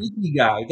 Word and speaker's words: ikigai: How do you pronounce it? It's a Japanese term. – Ikigai.